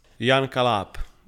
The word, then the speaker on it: Jan Kaláb: Jan Kalab. It's my name. J-A-N-K-A-L-A-B. – That's exactly how I would pronounce it, so that's lovely.